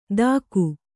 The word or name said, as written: ಥಾಕು